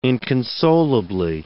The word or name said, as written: inconsolably